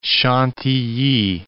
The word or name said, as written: Chantilly